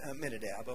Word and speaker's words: Amminadab: And then Perez, the father of Hezron, and Hezron, the father of Ram, and Ram, the father of Abibidibu, and Abibidibu, the father of Nashon. It's Amminadab, okay, I can pronounce it.